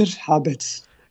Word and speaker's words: Habets: Okay, so in Flemish we just say Hunter Habets.